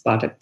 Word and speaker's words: Pathak: It's Pathak.